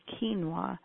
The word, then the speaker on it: quinoa: And you want to gear them towards eating something that's a little bit better, like rice or corn or quinoa, which is Q-U-I-N-O-A. It's not spelled like it sounds.